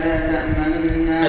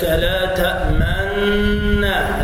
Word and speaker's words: تأمنا: قالوا يا أبانا ما لك لا تأمنا. – الإشمام هكذا، ما لك لا تأمنا، الإشمام للجميع، لا تأمنا. إذا قلت لا تأمنا خطأ، ضم الشفتين دون أن يظهر لذلك أثر في النطق.